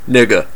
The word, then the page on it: nigga: Northern California; /ˈnɪɡə/